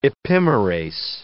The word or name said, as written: epimerase